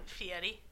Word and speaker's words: Fieri: That's not how you say his name. – How do you say his name? – Fieri.